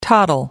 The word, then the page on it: toddle: American English